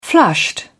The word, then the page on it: flushed: British English